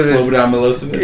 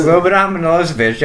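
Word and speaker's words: Slobodan Milošević: So Slobodan Milosevic. – Yes, yeah, Slobodan Milosevic.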